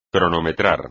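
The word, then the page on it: cronometrar: español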